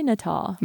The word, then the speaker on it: Nuttall: This is how she pronounced it. Let's say Nuttall. – Nuttall.